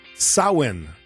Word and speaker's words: Samhain: Samhain, S-A-M-H-A-I-N. Looks nothing like Samhain, but that's how it's said.